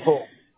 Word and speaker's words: Sebastopol: How do you say it? – Sebastopol. – Oh, gosh. – Sebastopol. – Yeah. Sebastopol.